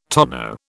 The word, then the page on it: tonneau: British English